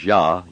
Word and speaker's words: Jah: See, J-A-H is Yah. You pronounce that Yah,